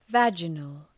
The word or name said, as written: vaginal